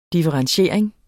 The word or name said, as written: differentiering